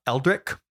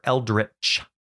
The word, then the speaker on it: Eldritch: They are not Eldric modifiers. Like our buddy Eldric. They are Eldritch.